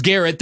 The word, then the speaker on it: Garrett: Myles Garrett, that does rhyme with ferret, just like Jarrett.